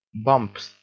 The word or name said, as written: bumps